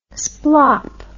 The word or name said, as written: splop